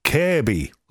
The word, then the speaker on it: Kirkby: Being born in Liverpool, we have a place called Kirby, and it's spelled K-I-R-K-B-Y, and you don't pronounce the second K. So it's pronounced Kirby, like that, Kirby.